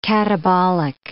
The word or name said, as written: catabolic